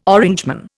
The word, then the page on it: Orangeman: Indian